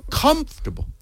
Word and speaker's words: comfortable: Pero es muy difícil para un hispanohablante decir la palabra en inglés, que es la misma palabra, pero es comfortable. Comfortable. pero no, es comfortable. Es que es, la primera sílaba.